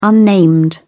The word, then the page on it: unnamed: British English